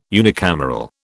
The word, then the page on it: unicameral: British English